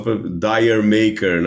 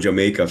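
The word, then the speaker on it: D'yer Mak'er: Agora, eu sou do time que gosta muito da música Jamaica, ou que se a gente lê com uma pronúncia mais aproximada da nossa, o pessoal talvez não entenda, né? O pessoal, Dire Maker, né? Que a gente geralmente fala, mas é Jamaica, claro, é para soar como Jamaica, afinal é um reggae, né?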